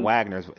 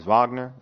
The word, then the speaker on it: Wagner: It's not Wagner. It's Wagner. It's like a V, Wagner, right? – Wagner. – Yeah, Mo Wagner. – I mean, yeah. – So a player like Mo Wagner has to, like, I think this is a great opportunity because for a while, right, he wasn't getting any burn.